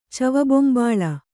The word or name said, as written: ಚವಲಬೊಂಬಾಳ